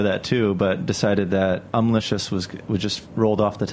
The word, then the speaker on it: Umlicious: Also joining us today is a company called, I may not pronounce this entirely correctly, Umlicious. – That's correct.